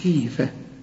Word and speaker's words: خيفة: منهم شوف خيفة، احنا قلنا الخاء قبل كده المفتوحة خاء صح، انما لما تكون مكسورة تبقى خي، لكن ما نقولش انها مرققة، هي في اخر درجات التفخيم نقول منهم خيفة.